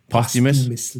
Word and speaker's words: posthumous: How do you say it? – Posthumous. It's a hard word.